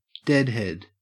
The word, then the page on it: deadhead: Australian English